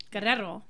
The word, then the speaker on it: Guerrero: Guerrero. – One more time. – Guerrero. – Guerrero. – Guerrero. – Yeah, that's how I would have said it. – Guerrero.